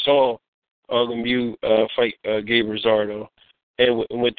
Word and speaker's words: Lemieux: Lemieux.